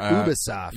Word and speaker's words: Ubisoft: Do you say Ubisoft or Ubisoft? – Ubisoft. – Ubisoft? Is that how you pronounce it? – It is.